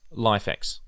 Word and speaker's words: LIFX: So let's talk a little bit quickly about LifeX. That's spelled LIFX.